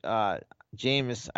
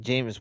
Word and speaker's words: Jameis: I mean, and I'm not a big Jameis. I said his name right, Jameis Winston fan.